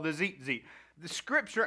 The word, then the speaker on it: tzitzit: T-Z-I-T-Z-I-T. Tzitzit. I don't understand Hebrew, and I don't ever claim to, but that's how it's, Mike told me that's how it's pronounced, because I asked him. You know, I redneck everything. I'm like, tzitzit. You know, like, but Mike informed me it is pronounced. He's been to college for these things. That it is tzitzit.